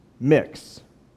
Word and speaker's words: Mx: My prefix, she says to the class, is MX, pronounced mix.